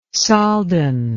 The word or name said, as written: soldan